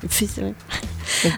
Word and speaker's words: Pfister: Troisième film, Transcendance, que vous pouvez voir en 3D ou pas, réalisé par Wally Pfister. Je sais pas comment on prononce ça. Comment on prononce un P et un F ? Pfister ? Pfister, oui.